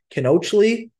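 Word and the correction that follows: Kincheloe: I know I said that wrong. – Kenchalo. – Kenchalo. Thank you.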